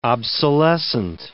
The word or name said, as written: obsolescent